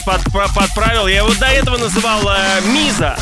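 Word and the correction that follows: Mizo: но оказывается он произносится Мизо.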